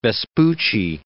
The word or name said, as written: Vespucci